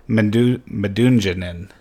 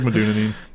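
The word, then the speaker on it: Medunjanin: Rito Ziegler at number 3 from FC Dallas, Neto Manuoha at number 4, and Harris Madunjanin. – Nope. That's not how that's pronounced. – Is it not? – No. It's like Madunjanin. – Oh, wow. – Something like that.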